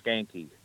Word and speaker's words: Schanche: Brian Skanky. S-C-H-A-N-C-H-E.